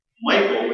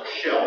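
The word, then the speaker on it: Michal: Michael, which can also be pronounced Michelle, if you need to have the identity situation going,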